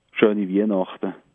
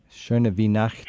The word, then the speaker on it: Schöne Weihnachten: Schöne Weihnachten. – Schöne Weihnachten. – Schöne Weihnachten.